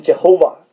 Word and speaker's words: Jehovah: Adonai 是可以发音的，所以呢，他们想到 Adonai 里面的三个元音呢 AOA 把它放到那个 YHWH 插到中间呢，这个发音就可以发出来。其实照着 YHWH 加上 AOA 的发音呢，英文就变成 Jehovah，那么中文呢就直接照这个音译过来呢，就变成耶和华。